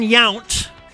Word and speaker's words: Yount: Robin Yount. That's how you say his name.